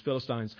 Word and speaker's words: Philistines: that's Philistines to you, Dan, it's the correct pronunciation is Philistines.